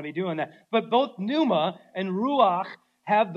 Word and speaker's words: pneuma: Everybody say the word pneuma, right? Pneuma. Yeah, it's kind of a fun word to say. It actually starts with a P. If you didn't know that, the P is silent, right? It's pneuma.